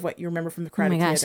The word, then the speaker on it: karate: And last but not least is karate or karate, right? Is that how you pronounce it? – Karate.